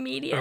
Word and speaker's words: median: and she was expecting me to say Midian. – You mean median? – Or median, sorry. Median. I saw the word, I just said it wrong.